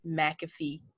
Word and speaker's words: McAfee: McAfee Bryant. McAfee is spelled like the antivirus software, and no, we're not related.